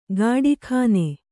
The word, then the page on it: ಗಾಡಿಖಾನೆ: gāḍi khāne